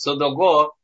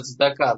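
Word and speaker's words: цдака (צדקה): Да, то есть содого это цдака, да, как принято говорить, цдака. Тут сразу несколько assumptions. – Очень которые... похоже на ашкеназское произношение «цдоку», а тут «содого».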